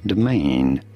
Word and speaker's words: domain: Domain, you do want to stress on the second syllable, on the main syllable.